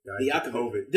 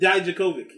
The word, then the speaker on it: Dijakovic: Dominic Dvokovic, or however you say his name. – Dominic Dijakovic. – Dijakovic. – Yeah. – There you go.